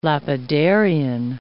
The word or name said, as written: lapidarian